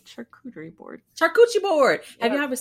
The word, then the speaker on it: charcuterie: They're not even saying it right, aren't they? – No, yes, yeah, not. – It's charcuterie board. Charcuterie. Right? Yeah, I said it wrong.